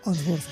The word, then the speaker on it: Unsworth: No sé si se pronuncia así. Geoffrey Unsworth. Bueno. – Unsworth, sí.